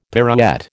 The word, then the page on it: pirouette: American English